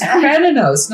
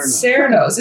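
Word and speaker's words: Cernunnos: It's Krenanos, not Serenos. This should be the Boston Celtics, not the Boston Celtics. Because there's no soft C.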